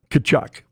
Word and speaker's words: Tkachuk: How do you pronounce that? – Kachuk. – Because you sure don't spell it like that.